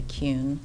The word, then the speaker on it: Kuhn: My name is Julia Kuhn. Last name is spelled K-U-H-N.